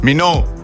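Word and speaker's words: Minogue: A znaš izgovoritni primek? – Ja, znam. Minou. – Bravo.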